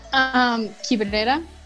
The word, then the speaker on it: Quibrera: Did I say that correctly? – Yeah, kind of. – How do you say it? – Yeah, that's good. Quibrera.